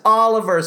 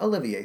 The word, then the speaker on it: Oliver: Olivier. Oliver? No. Yes, Oliver Smith. Not Olivier Smith.